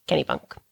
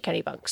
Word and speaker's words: Kennebunk: But I uh, looked it up multiple times, multiple different places, and they all said it was Kennybunk. it's spelled K E N N E B U N K. I mean, I trust you. I even I found an article from a radio station in Maine that was like commonly mispronounced towns, and they said you pronounce it Kennybunk.